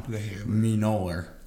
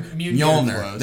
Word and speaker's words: Mjolnir: And then it close ups on Mjolnir. Yeah, I know I pronounced that wrong. Mjolnir. There we go.